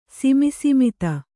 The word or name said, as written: ಸಿಮಿಸಿಮಿತ